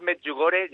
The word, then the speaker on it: Medjugorje: Con decir Medjugorje ya es para nota, ¿eh? – Medjugorje.